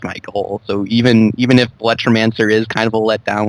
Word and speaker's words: Bulletromancer: I pronounce it Bulletromancer. – Yeah, Bulletromancer. – So one word.